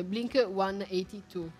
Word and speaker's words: Blink 182: in un tweet subito fu chiesto, diciamo, a lui quindi la soluzione dell'enigma, chiedendomi come lui lo pronunciasse. E la risposta è stata Blink 182.